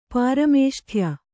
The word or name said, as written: ಪಾರಮೇಷ್ಠ್ಯ